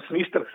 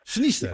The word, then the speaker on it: Sniester: Spreek ik trouwens goed uit, want er is nog wel eens verwarring over hoe je dat nou precies moet uitspreken. – Ja, het is gewoon Sniester, zoals je het zegt.